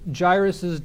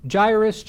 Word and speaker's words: Jairus: Jairus, Jairus, you hear it all kinds of different ways. How in the world do you pronounce it? In the Greek, it's Yerus, Yerus. In the Hebrew, it's Yair. Yair.